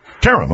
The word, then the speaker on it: Karam: It's pronounced Karam. – Karam, okay. – And I'm told that that's an Americanized mispronunciation of the original, but it works for us.